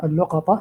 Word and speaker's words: اللقطة: كلامنا اليوم إن شاء الله تعالى في اللقطة، وهي تنطق هكذا: اللقطة بضم اللام وفتح القاف مع الطاء، لقطة مأخوذة من لقط الشيء من الأرض، أي رفعه من الأرض.